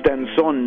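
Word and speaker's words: danzón: He is Piece Danzon number two. Uh, it's one of his most uh, performed pieces, orchestral pieces. And when I say danzon, you know, it, rhymes with dance somehow.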